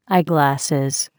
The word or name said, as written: eyeglasses